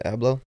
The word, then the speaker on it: Abloh: What's his last name? Abloh? – Yeah. – Is that how you say it? Abloh? – Virgil Off-White.